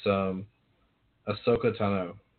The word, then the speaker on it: Ahsoka Tano: But it's Ahsoka Tano.